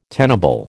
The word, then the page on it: tenable: American English